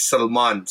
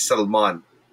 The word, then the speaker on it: Salman: Can you call me Salman? – It's Salman. So when you say it in our dialect, it's Salman.